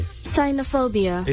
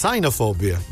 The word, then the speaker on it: cynophobia: Cynophobia. – It's cynophobia.